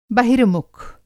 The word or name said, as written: बहिर्मुख